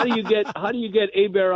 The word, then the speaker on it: Hebert: The quarterback of the Saints is Bobby Hebert. You know, it's spelled Herbert. – H-E-B-E-R-T.